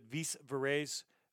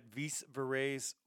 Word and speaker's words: Vis Vires: They're at Vis Vires Oi on both Instagram and Facebook. And in case I'm mispronouncing that, it's V-I-S-V-I-R-E-S.